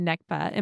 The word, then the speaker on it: Nakba: So I know that you're talking about, like, your family coming back after the Nakba.